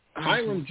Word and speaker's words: Hiram: strategically connected her with Pastor Stephen Mortimer, pastor of Life Eternal Ministries, located in, and I hope I'm pronouncing this correctly, Hiram, Georgia? Is that Hiram, yes. – Georgia? – Correct. – Hiram, Georgia, and you served there for the past 4 years.